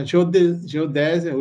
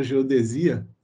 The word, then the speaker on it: geodésia: E ele continuou ministrando as aulas para os alunos de engenharia civil para a geodésia, ou geodesia, depende da pronúncia, e astronomia de posição.